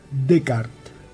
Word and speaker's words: Descartes: En francés, las letras S que están al final no se pronuncian. Y habitualmente tampoco se pronuncian las E que estén al final, salvo que estén acentuadas, como René. El tipo se escribe Descartes, pero la S la pasamos por alto, la dejamos para otro día, salvo que estuviera con una vocal en lo posible fuerte. Así que aunque se escribe Descartes, se dice Descartes.